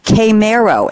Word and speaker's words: Chimero: And RJ has sent us a quote from Frank and I have the pronunciation here, Chimero, and it's C-H-I-M-E-R-O.